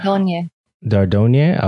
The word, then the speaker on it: Dordogne: Kiwi, Door, Don, okay, that's how I'll say that. – Dordogne. – Okay, appreciate that. – Yeah, it's French, and I'm still trying to figure out the correct way to say it.